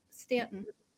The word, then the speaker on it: Stanton: Is that how you say your last name, Ann? – Yep, Stanton.